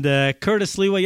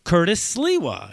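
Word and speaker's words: Curtis Sliwa: And Curtis Liwa, yeah, I pronounced it right.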